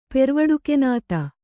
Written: ಪೆರ್ವಡುಕೆನಾತ

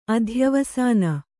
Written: ಅಧ್ಯವಸಾನ

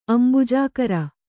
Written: ಅಂಬುಜಾಕರ

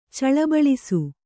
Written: ಚಳಬಳಿಸು

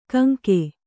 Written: ಕಾಂಕೆ

